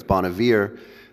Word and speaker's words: Bon Iver: And I think for me, as I mentioned the other day in talking about Bon Iver, and that's actually how he pronounces it. I heard him pronounce that in a concert. I pronounced it differently, but it's Bon Iver, not how I pronounced it.